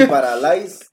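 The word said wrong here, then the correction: Lay's: Sí, para, Lais, Lais y Doritos.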